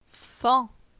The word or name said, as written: Fan